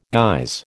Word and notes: guise: American English

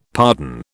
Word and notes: pardon: British English

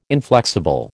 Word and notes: inflexible: American English

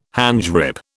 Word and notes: handgrip: British English

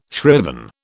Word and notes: shriven: British English

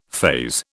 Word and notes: faze: British English

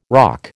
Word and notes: ROC: American English

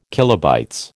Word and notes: KB: American English

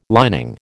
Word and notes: lining: American English

